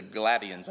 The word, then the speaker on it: Galatians: Like that's because the one book that I left out was To the Galatians, I think is how we learned how to pronounce it tonight.